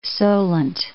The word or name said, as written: Solent, The